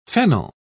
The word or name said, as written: fennel